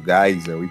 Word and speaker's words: Geisel: Hoje a gente fala do General Ernesto Geisel, e por favor, não pronunciem Geisel. Geisel não existe, é Geisel.